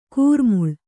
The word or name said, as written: ಘೋರಾಮ್ಲಿ